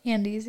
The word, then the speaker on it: Andes: One summer, I decided to hike the Andes by myself. Is that how you say it? – Andes, yeah.